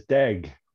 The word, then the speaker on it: Deg: Last name is Deg, rhymes with leg.